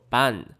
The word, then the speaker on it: 番: 番这个字呢，在日本的汉字里面念作 ban。